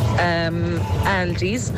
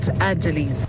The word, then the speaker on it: Aldi's: My mother, she can never pronounce Aldi's, so she calls it Adelies.